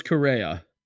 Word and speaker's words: Correa: Carlos Correa.